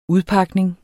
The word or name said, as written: udpakning